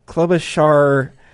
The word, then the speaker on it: Klobuchar: I don't think that what Klobuchar did worked. And I think said her name right. – Klobuchar.